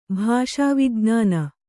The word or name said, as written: ಭಾಷಾವಿಜ್ಞಾನಿ